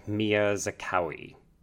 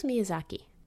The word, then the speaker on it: Miyazaki: And it was directed by the master himself, Hayao Miyazaki. No. – That was Miyazaki.